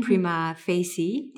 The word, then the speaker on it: Prima Facie: So I'm very excited with your next project, Prima Facie.